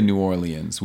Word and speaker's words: New Orleans: How do you prefer to pronounce that? – I say New Orleans, which is not how most people say it.